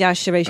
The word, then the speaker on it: Yashere: It's pronounced Yashere because she's Nigerian.